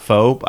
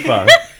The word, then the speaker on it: pho: I really like, I believe it's actually pronounced pho, not faux. I always mispronounce it, though. – Yeah, it's pronounced pho. The reason you know it's pronounced pho is because every strip mall pho joint that thinks they're clever calls himself the pho king.